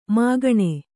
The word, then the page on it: ಮೈಗಣ್: māgaṇ